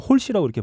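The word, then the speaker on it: Halsey: 한박에 치고 올라온 제가 지난주에 영국 차트 소개해드리면서 한번 헬시라고 그랬었는데 음, 음. 정확히는 뭐 홀시라고 이렇게 발음한다고 예.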